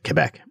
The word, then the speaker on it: Quebec: And actually, I think 2021 was actually the first year where Quebec, a lot of listeners have been getting upset with me for not pronouncing it properly. So they go, Quebec, I'm going to make an effort to do that properly because it does matter.